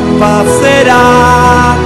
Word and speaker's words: Passerà: Con l'accento sulla A. – Sulla A. – Finale. Che l'avevo scritto male io. A dopo. – Passerà,